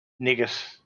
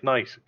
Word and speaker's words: knight: Knight as N-I-T-E makes perfect sense. Niggit. I mean, I'm not pronouncing all those letters. Like, knight, cool. Thrugga. No, through. T-H-R-U.